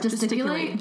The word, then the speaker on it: gesticulate: I need I oh, to just calculate yeah. wildly. Um, just calculate? Yeah. Is that not the right word? Gesticulate. gesticulate. Oh my god, I've been saying it wrong for so many years.